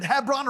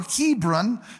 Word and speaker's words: Hebron: The second time was at Hebron or Hebron, depending upon how you want to pronounce it, wherever you come from. It's like Gala and Gala.